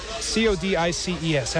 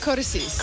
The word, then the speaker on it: Codices: Has CODICES. How do you pronounce it? – Codices. – Codices.